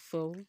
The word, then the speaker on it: faux: And the first one is F-A-U-X, which is actually pronounced four.